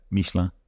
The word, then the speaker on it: Michelin: Or as you pronounce it. – Michelin. – Yeah. – Well, it's hard to do the French thing.